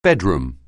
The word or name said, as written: bedroom